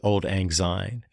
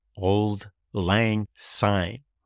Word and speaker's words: Auld Lang Syne: We pronounce it Auld Lang Syne in America, but it's Auld Lang Syne, 3 separate words, and the last word is an S, not a Z, in proper Scottish form.